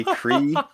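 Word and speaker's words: Cyre: I'm probably mispronouncing it. It's C-Y-R-E, Cree.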